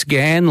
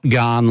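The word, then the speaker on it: Ghan: So I do appreciate it, and I'll never forget that it's Gan like Nan, not Garn like I've gone on the Gan.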